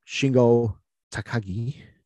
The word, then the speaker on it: Shingo Takagi: During that time, the man that we're about to talk about, Shingo Takagi, am I saying that right? – Yep.